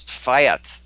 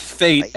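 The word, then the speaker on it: Fayt: It's F-A-Y-T. – Nice. – That's Fiat. That's Fiat. Fiat. Fiat. – No, it's Fate. Every character in the game pronounces it Fate.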